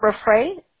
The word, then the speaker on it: Raffray: Your last name, you guys spell it R-A-F-F-R-A-Y, Yes. – How do you pronounce that? Raffray? – Raffray.